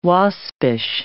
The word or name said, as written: Waspish